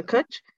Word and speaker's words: Kutch: And Sam was like, Kutch? I'm pretty sure that's how it's pronounced. A Kutch? He's like, that's right, you remembered.